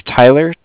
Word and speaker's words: Tyler: Yeah, my name is Tyler, T-Y-L-E-R, mm-hmm. – And I'm calling with Cardiff. It's spelled C-A-R-D-I-F-F, as in Frank Frank.